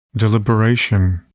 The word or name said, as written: deliberation